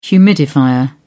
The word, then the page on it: humidifier: British English